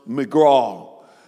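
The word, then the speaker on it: miqra: The word convocation is a Greek word that's pronounced megraw, and megraw means rehearsal.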